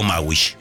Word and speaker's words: Maschwitz: Ah, Maschwitz. ¿Cómo se dice? Yo digo Maschwitz. – ¿Y la S? – Bueno, y bueno, me la como, no sé. – Bueno, ¿y la S? ¿La CH?